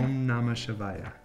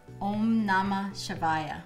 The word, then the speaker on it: Om Namah Shivaya: So Om Namah Shiva. How do you say that again? – Om Namah Shivaya. – Om Namah Shivaya. – Perfect.